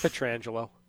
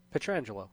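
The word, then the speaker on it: Pietrangelo: It's Petrangelo. – You say Petrangelo? – Petrangelo, yeah.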